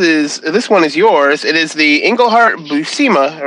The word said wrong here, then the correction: Buscema: or how do you say his name? Buscema.